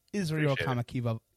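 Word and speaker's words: Israel Kamakavivole (Israel Kamakawiwoʻole): Israel Kamakavivole.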